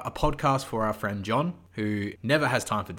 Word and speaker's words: D'John: And second thing, hello and welcome to our podcast, John and Dragons, where the first D is silent.